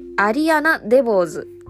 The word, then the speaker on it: アリアナ・デボーズ: えー、アリアナ・デボーズ。えー、ウエストサイドストーリーに出演されていました。アリアナ・デボーズが、お正解はい、答えも、えー、合っていました。